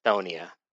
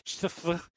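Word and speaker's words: Chthonia: The guy who reads the book, Wil Wheaton, says Thonia. Okay. All right. I'm sure he looked at it and said, fuck that. Chthoniya. Chthoniya. That's okay. CH is silent. Thonia. We'll move on from there.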